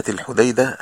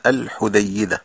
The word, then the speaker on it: الحديدة: وطبعا مدينة الحديدة هذا يعني اسمها الصحيح كما ينطق في اليمن، لأن بعض الإخوة المصريين هنا في مصر كانوا يقولون الحديدة، والصحيح أنها مدينة الحديدة بتخفيف الدال وليس بتجديد الدال.